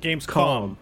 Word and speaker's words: Gamescom: about Gamescom, which I tend to mispronounce. I always think there's an N at the end, like Games Conference, but it's an M, like... – Yeah, Gamescom, like as in .com.